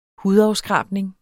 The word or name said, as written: hudafskrabning